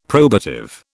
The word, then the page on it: probative: British English